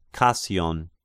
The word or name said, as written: Kasion